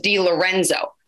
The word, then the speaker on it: DeLorenzo: And yet my entire life, I don't think I can count on one hand how many times people have pronounced my name, my last name properly, which is DeLorenzo.